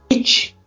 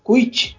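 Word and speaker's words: Kuyt: fazendo essa dupla com o Kit, Kuit, enfim, como queiram, porque pronúncias em holandês é um negócio complicado.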